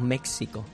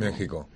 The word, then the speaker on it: México: Pero hay que decir México o México. – Hay que decir México. – México. – México. Con J, pero se escribe con X.